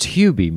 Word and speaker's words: Hubie: It's pronounced Hubie.